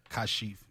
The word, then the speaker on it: Kashif: Did I pronounce Kashif correctly? – Yes. Kashif, yes.